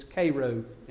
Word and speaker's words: Cairo: It is in Cairo, Mississippi, which if you ever see the sign driving down Highway 72, you will call it Cairo, because it's spelled like Cairo, Egypt, but it's Cairo in that part of Mississippi.